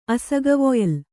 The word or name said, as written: ಅಸಗವೊಯ್ಲು